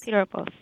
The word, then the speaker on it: Piropos: And then your essay, and I want to make sure I pronounce it correctly, is it Piropos? – Piropos.